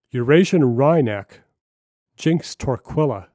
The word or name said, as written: Eurasian Wryneck